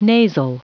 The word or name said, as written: nasal